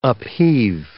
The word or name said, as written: upheave